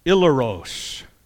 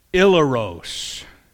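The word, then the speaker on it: ileros (ἱλαρός): Looks like this, and it's pronounced ileros. Ileros. And this is where we get our English word, hilarious.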